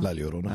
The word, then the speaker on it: La Llorona: Yada Llorona? – La Llorona. – I can't. – Chavez. – He is directing. – It's only a Y sound if there's two L's together, right? – La Llorona.